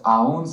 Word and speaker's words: aún: And that's aún spelled with an accent mark above the U.